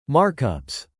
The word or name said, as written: markups